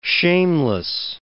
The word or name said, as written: shameless